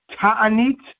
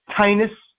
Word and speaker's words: Tainus: It's tractate Ta'anit. That's Israeli Hebrew style. Tainus is how you say it in the yeshiva world. Tainus.